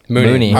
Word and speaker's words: Mooney: Mooney.